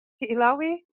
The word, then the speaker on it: Hi'ilawe: Am I pronouncing that correctly, Tanya? Hilawe? – Oh, most definitely.